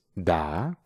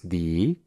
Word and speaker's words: the: En esta parte, un truco interesante de pronunciación es que la palabra da, que es lo que en español significa como el, las o las, tiene dos pronunciaciones. La primera es da, cuando su siguiente palabra inicia con una consonante. Y la segunda es di, cuando su siguiente palabra inicia con una vocal.